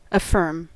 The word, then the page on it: affirm: American English